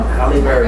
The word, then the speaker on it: Halle Berry: Halle Berry, yeah.